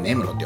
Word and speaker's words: 根室: なんだけど、札幌駅の中に入ってるんだけど、えー、根室花丸というですね、根室、根室ってわかりますあの根っこの根って書いて、室内の室って書いて根室って読むんですけど、